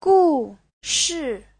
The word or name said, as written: gu4shi4